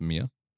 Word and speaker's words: Mir: Space Station Mir, I believe it's pronounced Mir, M-I-R.